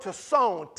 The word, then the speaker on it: tsone: When it says for sheep, it is the Hebrew word tsone. T-S-O-N-E is the how you would the phonetic pronunciation of that. Tsone.